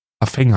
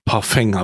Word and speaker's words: Parfum: Spice Bomb Parfum habe ich jetzt hier. – Parfeng heißt das, wird das ausgesprochen. – Parfeng habe ich jetzt hier.